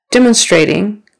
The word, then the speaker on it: demonstrating: Demonstrating. First syllable stress again. Demonstrating. Kind of first and then third.